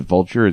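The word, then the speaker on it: Vultr: Maybe you're supposed to pronounce it Vulture. It's V-U-L-T-R.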